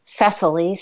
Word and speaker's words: Cecily: It's an unfamiliar name, and, you know, even though Cecily, C-E-C-I-L-Y, if you see it and if you take the time to sound it out, it's quite phonetic.